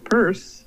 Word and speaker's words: Peirce: You're pronouncing his name Peirce, which I was getting wrong.